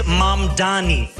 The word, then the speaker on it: Mamdani: The name is Mamdani. M-A-M-D-A-N-I. You should learn how to say it.